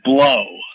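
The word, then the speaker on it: Blough: The main source, his name is, it's Joe, and the last name is spelled B-L-O, UGH, which is, he pronounced it blow.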